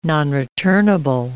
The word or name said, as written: nonreturnable